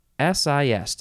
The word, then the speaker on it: Ecdysis: Ecdysis.